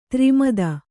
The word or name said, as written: ಧರ್ಮೇತ